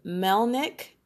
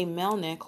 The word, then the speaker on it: Melnyk: Ontario Police Officer Jay Melnick, and that is, the last name is spelled M as in Mary, M-E-L-N-Y-K. Again, that's Jay Melnick, spelled M-E-L-N-Y-K.